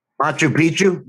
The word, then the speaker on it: Machu Picchu: a virtual manchu Poochu. I, I always mispronounce that mispronounce that hold on um Machu Picchu? – Yeah, virtual.